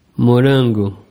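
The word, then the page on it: morango: Brazilian Portuguese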